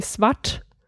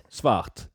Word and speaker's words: svart: So, now I don't find the examples here, but so, for example, there's a word like black would be svart. – Yeah. – So how do you say it? – Svart. – Svart. So you really pronounce the R and the T and So.